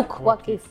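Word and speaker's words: Souq Waqif: Sukwakif.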